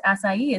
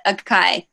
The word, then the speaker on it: acai: That's how you pronounce acai. Is that correct? – Yes, that is correct. Most people do pronounce it acai.